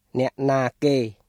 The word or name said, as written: អ្នកណាគេ